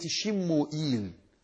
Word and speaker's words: شموئيل: هذا النبي هو صموئيل عليه السلام، ويقال له بالعبرانية شموئيل، والعرب ينطقونه السموئل، يعني إذا سموا هذا الاسم يقول السموئل.